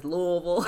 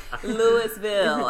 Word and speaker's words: Louisville: I have to be careful because everybody says Louisville. So I have to pronounce every syllable. Louisville.